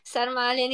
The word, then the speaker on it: sarmale: So the first one is sarmale.